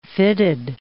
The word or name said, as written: fitted